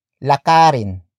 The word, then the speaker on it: lakarin: If a root word ends with D, it is switched into R before adding the in suffix. For example, lakarin, lakarin, lakarin.